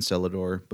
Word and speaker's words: Celladore: I had never seen Celador before. Which, if you're just listening, is spelled Celladore, not like cellar door.